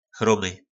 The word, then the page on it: chromy: po našimu